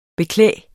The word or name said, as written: beklæde